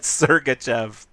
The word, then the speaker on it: Sergachev: I mentioned how every time I saw Sergachev, is that how you pronounce it? – Sergachev.